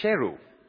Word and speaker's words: Sheru: And that's when Saru learned that his name was actually Sheru,